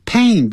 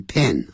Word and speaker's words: pain: pain 不要念成 pen, a 在 n 的音 pain,